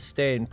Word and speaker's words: Stane: But he is so pissed at Obi-Wan Stone that he puts Obi-Wan Stane, pardon me. I keep getting his name wrong.